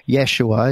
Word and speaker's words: Yeshua: or Yeshua, as you pronounced rightly.